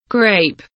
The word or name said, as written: grape